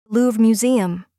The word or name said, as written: Louvre Museum